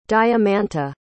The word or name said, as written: diamanta